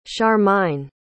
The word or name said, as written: charmine